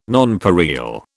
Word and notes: nonpareil: British English

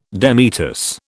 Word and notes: demitasse: British English

